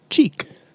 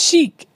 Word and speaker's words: Cheek: We can do a lot of cross promotion between our shows and your clothing brand, Cheek. – It's pronounced chic. It's French. – But it's spelled C-H-E-E-K.